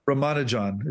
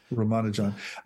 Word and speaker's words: Ramanujan: Ramanujan is his name. Ramanujan.